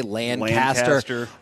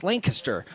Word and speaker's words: Lancaster: I'm glad you say it right, too. – Because I don't say Lancaster. I will admit, it's Lancaster.